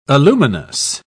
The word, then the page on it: aluminous: British English